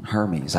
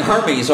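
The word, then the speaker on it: Hermès: Spent money on Hermes. I didn't even know what Hermes was. Luxury bags. – Hermes. – Are they men's? – Hermes. It's pronounced Hermes.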